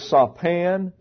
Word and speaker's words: sapan: The first one we would note is sapan. It is spelled S-A-P-A-N.